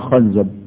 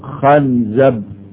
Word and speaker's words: خنزب: خنزب، فعلماء اللغة قالوا تنطق خنزب بفتح الخاء وخنزب واخد بالك وخنزب، فالخاء تقرأ مفتوحة وتقرأ مكسورة وتقرأ مضمومة،